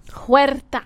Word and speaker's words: Huerta: She's no Paz de la Huerta. Again, am I pronouncing that right? Is it Huerta? – Huerta. – Huerta. Huerta. Now, mind you, I believe the name is, in her case, Spanish, not, you know, Nicaraguan or something.